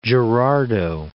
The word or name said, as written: Cape Girardeau